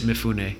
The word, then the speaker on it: Mifune: Mifune, is that how you say it? – Is it M-I-F-U-N-E? – Yes. – Mifune. – Okay, Mifune. He's done a bunch of serious martial arts movies in the 50s and 60s.